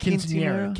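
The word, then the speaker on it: quinceañera: Quinceanera. Am I pronouncing that right? – Quinceanera. Quinceanera. – Quinceanera. Right? – Yeah.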